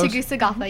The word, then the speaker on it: Tegucigalpa: Now, how do you say, is it Tegucigalpa or something? – Yes. – Am I close? – Tegucigalpa, yes.